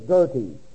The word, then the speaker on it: Goethe: 1749, Johann, J-O-H-A-N-N, Wolfgang, W-O-L-F-G-A-N-G. The correct pronunciation is Goethe, G-O-E-T-H-E.